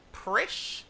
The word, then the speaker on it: Presh: Presh. So it's a P. And it doesn't make any sense. – Wow. – It's still pronounced like fresh, but with a P.